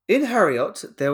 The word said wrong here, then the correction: Heorot: Near Heorot